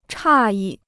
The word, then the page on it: 诧异: chà yì